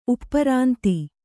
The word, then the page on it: ಉಪರಾಂತಿ: uparānti; Kannada